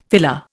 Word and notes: filler: Indian

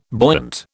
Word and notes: buoyant: British English

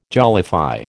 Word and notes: jollify: American English

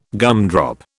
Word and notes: gumdrop: British English

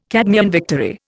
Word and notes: Cadmean victory: Indian

